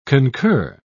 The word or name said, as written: concur